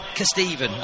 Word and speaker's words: Kesteven: By the way, I'm pretty sure that the Kestavinian rhymes with heaven, not Stephen. That's from Fellsworth. – K-E, Stephen, Kesteven, that's what I'm calling it, see.